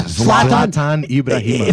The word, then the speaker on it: Zlatan Ibrahimovic: Zlatan Ibrahimovic.